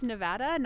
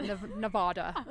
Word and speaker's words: Nevada: It's Nevada, not Nevada. – Nevada, not Nevada, yeah.